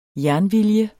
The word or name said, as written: jernvilje